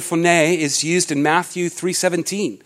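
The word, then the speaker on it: phoné (φωνή): The Greek word for voice here is pronounced phoné. It's pronounced phoné,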